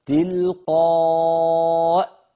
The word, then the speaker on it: تلقاء: الهمزة على شكل الياء تلقائي، لكن اللفظ همزة مكسورة، ففي الوصل نقول تلقائي نفسي، فإن وقفنا قلنا تلقاء تلقاء، يعني الياء المرسومة لا تؤثر في النطق لا في الوصل ولا في الوقف، فلا يغترن بها، هي كرسي للهمزة، الياء هنا كرسي للهمزة، وهذا مخالف لما اصطلح الناس عليه من بعد ذلك في الإملاء الحديث.